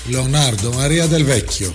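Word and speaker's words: Leonardo Maria Del Vecchio: Λεωνάρντο Μαρία Ντελ Μαρία. – Μαρία. – Λεωνάρντο Μαρία Ντελ Βέκιο.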